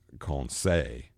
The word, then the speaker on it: Conseil: Now, Aronnax does have a really dedicated servant, and it's spelled Conseil. In the book, but it's actually conseil, and it actually does mean counsel, like, you know, to give advice.